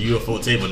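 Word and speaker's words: ufotable: I know UFO is part of the word, but I always say Euphorable because I hate saying words that are broken up, like 10 syllables. I just It's always been pronounced. You're supposed to say UFO to me.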